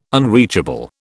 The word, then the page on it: unreachable: British English